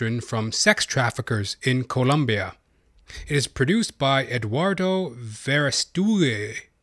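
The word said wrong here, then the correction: Verástegui: Pardon my pronunciation there. Eduardo Verastugue, who also plays a role in the film.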